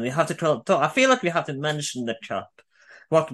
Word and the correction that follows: coup: In English pronunciation, coup is how you say it.